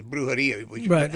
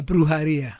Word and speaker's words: brujería: And then Oquendo was, she was the brujería. How do you pronounce it again? – A brujería. – Brujería, right. Which is like a witch.